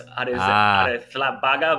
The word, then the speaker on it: Arrizabalaga: You're going to talk to us about Kepa Arrizabalaga? Is that how you pronounce it? – Yes, the only thing is on the Z, it's a TH, so it's Arrizabalaga, but it's even for a Spaniard, it's very hard to say.